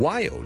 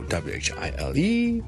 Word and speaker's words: while: 再俾多一個例子，大家譬如 while w h i l e，有啲人就將佢讀成咧 while，就係咁啦。